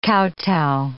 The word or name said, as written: kowtow